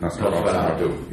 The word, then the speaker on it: Nosferatu: Nosferatu.